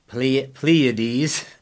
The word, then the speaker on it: Pleiades: How long Pleiades, if I'm saying that correctly. You're cringing, so I must not be. – No. It's good. You're good.